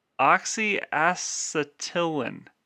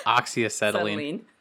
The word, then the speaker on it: oxyacetylene: It's oxyacetylene.